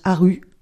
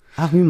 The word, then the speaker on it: Harry: Ça se prononce Haru. Haru Martinson. – Ah oui, c'est vrai. – C'est le Y suédois qui se prononce U.